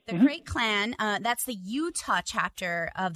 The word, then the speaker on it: Krayt: Which means Sergeant in Arms from the Krayt clan. Did I say it? – Krayt clan, exactly.